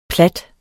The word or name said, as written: plat